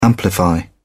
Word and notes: amplify: British English

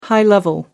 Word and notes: high-level: American English

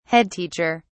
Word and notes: head teacher: British English

